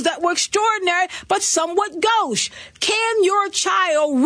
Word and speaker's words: gauche: I will give you gauche, because gauche is pronounced gauche, but it's spelled G-A-U-C-H-E.